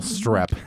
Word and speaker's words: Streep: Also, Bart's girlfriend starring Meryl Streep. Gosh. Am I pronouncing that correctly? – Yes. Meryl Streep.